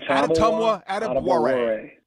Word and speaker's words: Adetomiwa Adebawore: Well, let's say it together now. Adetomiwa Adebawore. There, we, there we go. I said it. Yes. – Got it right.